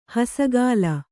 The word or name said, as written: ಹಸೆಗಲ್ಲು